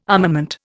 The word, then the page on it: armament: Indian